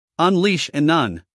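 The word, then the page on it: unleash: uk ʌnˈliːʃ; en-us ʌnˈliːʃ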